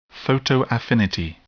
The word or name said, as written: photoaffinity